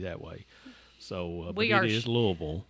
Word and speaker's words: Louisville: And it is pronounced Louisville for those folks that are listening. – Louisville. – Yeah, it's not Louisville, and I don't know who came up with that, but that is not how you say that.